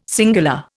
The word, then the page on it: singular: Indian